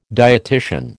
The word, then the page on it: dietitian: American English